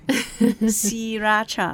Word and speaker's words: Sriracha: Even we spell it out S R I, but the R is not pronounced. It's something in Thai language that we call is a false cluster. – Wow. Siracha.